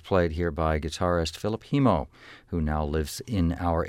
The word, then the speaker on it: Hemmo: Actually, you know, I said Hemo. I believe he says Hemo. I remember I was surprised by that. It's spelled H-E-M-M-O, but he says Hemo, Philip Hemo.